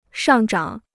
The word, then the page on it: 上涨: shàng zhǎng